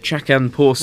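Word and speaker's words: Chacun Pour Soi: I don't really know too much about, how do you pronounce it? – Chacun Poursoir.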